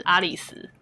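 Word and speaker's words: 有栖: 有希有希良平，我们都叫有希。嗯、那因为有希这个在日文的发音就叫爱丽丝、阿里斯，念作阿里斯。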